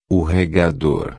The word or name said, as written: regador